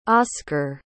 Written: osker

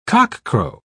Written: cockcrow

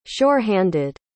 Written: sure-handed

